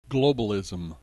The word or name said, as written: globalism